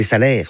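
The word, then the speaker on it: Salers: Je m'y connais en vaches, des salaires, on ne prononce pas le S.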